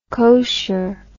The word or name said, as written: cosher